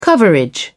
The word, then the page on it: coverage: British English